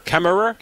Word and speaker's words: Kemmerer: Kemmerer. – Kemmerer. – Kemmerer. – Kemmerer. – Wyoming. – K-E-M-M-E-R-E-R.